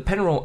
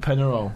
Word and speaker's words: Peñarol: But Peñarol are the oldest football team in Uruguay, as they were formed in, of course, 1891. They were the footballing element of that club. And the name Peñarol was taken from a little neighbourhood village on the outskirts of Montevideo.